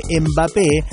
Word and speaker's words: Mbappé: Dice, no, porque Mbappé, y no es Mbappé, no hay una E adelante ni tampoco es un nombre inglés como para decir M, que es la pronunciación de la letra M, es de otro país y hay que decirlo como se dice en el país, básicamente, ¿no?